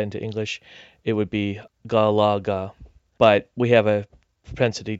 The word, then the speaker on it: Galaga: You can say Garaga. Right, just ga-ra-ga. – Ga-ya-ga. – You know, that's how you would pronounce it in Japanese.